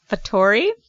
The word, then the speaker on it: Fattori: It's like F-A-T-T-O-R-I? – E. – Oh, Fattori. – Fattori. – It's an A sound, like it's Italian, so Italian. – They met online. – Fattori.